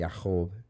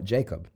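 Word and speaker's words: Yaakov (יעקב): And so if you're going to say it right in Hebrew, it'd be something like Yaakov. – Mm-hmm. – but we call it Jacob.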